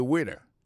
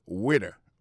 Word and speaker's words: waiter: Alors moi je prononce le waiter. Waiter ça veut dire accent tombé sous premier syllabe waiter. W A I T E R.